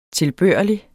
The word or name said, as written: tilbørlig